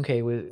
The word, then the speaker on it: Cay: So we did our day at Half Moon Cay, which was Half Moon Cay, excuse me. It's spelled K, but it's yeah.